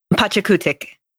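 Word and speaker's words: Pachakutiq: That's I don't think that's how it's pronounced. How do you think it's pronounced? – Hold on, I gotta look it up. – It's It's P A C H A K U T I Q. – Okay, it's Pachakutik.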